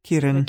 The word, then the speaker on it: Kirin: So that's how you say it, Kirin.